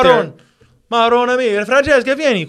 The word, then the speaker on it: Marone: It's spelled with a as like M A R O N E. – Yeah. – Pronounced Maron. Because when I heard it,